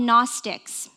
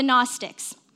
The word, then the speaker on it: Gnostics: There was a group of people called the Gnostics, silent G, kind of complicated, already kind of tells you how pretentious they were, the Gnostics.